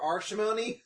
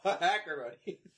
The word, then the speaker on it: Acrimony: And really, it's anybody, but it's most of the black people coming to see it who don't know how to pronounce Acrimony whatsoever. So they're just like, give me a ticket for Arshimony. Just like, what? Oh, Acrimony. One ticket for Ashotamony.